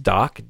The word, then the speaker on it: Dok: I'm going to call it, say it's pronounced Doc, D-O-K. And I say nasty because the payload that this malware has comes via a signed, yes, a developer signed application.